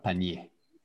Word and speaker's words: panier: Le R, il est silencieux. So silent R, panier.